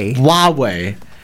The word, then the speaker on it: Huawei: So forget the H. It's like a silent H. So start with the... – The W. – Yeah, Huawei. – It's definitely not an English word. – Just think of it as a W.